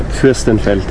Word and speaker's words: Fürstenfeld: Fürstenfeld? – Fürstenfeld.